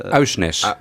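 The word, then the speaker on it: Aursnes: Uh, Ausnes.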